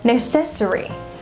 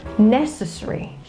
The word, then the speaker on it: necessary: And then the next one, I often hear people say necessary. Yeah, they stress on the second syllable, but it is not stressed right there. It is stressed on this first syllable and it goes necessary.